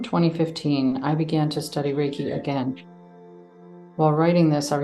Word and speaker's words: Reiki: Reiki, pronounced Reiki, means spiritually guided universal life force energy in Japanese.